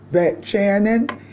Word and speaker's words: Vetchanan: Today we are in Torah portion number 45, which is called Vetchanan, and that is the Hebrew word meaning that I pleaded. Now, Vetchanan is spelled B-E-T-C-H-A-N-A-N. Vetchanan.